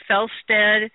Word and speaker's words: Fjelstad: It is at Amazon, so you just go to Amazon and put in my name, Felstead, which is spelled F-J-E-L-S-T-A-D, and it will pop right up.